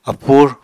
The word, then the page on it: apur: Apatani